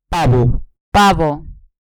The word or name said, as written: pavo